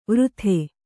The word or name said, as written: ವಾರತೆ